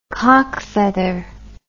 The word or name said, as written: coqfeather